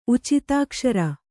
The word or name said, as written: ಉಚಿತಾಕ್ಷರ